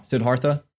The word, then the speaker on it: Siddhartha: Oh wow, Siddhartha.